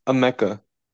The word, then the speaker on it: Emeka: Amika Ejbuka. – It's pronounced. I think this one is pronounced Emeka. – Oh, Emeka. Got it.